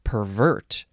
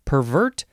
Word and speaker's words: pervert: Three, the synonym is just pervert, or actually, in this context, it would be pronounced pervert, as in pervert justice.